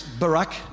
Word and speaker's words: Barak: The sixth is Barak, and you say it like the American president.